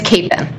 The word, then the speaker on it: Kapin: It's Kapin.